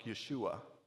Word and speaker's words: Yeshua: The sign on the cross, if we were to try to pronounce in Hebrew, would sound like Yeshua. You may have heard that, and it's an appropriate Hebrew way to speak it.